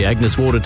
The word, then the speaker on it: Agnes Water: Heading down to Agnes Waters. Right. It's pronounced Agnes Water.